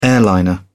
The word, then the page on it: airliner: British English